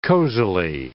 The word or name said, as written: cozily